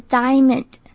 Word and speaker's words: diamond: Ideally，再来钻石的英文 diamond 的 IA 要发成长母音，发起来会像是 I 的声音一样，整个字发起来就是 diamond， diamond。